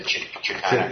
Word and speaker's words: Shekinah: Isn't it Shekinah? How you pronounce that word? The Shekinah glory.